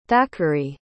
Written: thackary

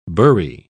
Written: burry

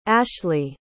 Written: ashly

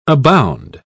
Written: abound

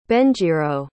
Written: benjiro

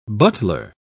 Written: butler

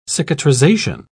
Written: cicatrization

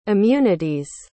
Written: immunity's

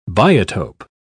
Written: biotope

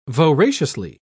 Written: voraciously

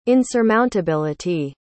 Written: insurmountability

